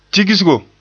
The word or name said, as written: tigsgo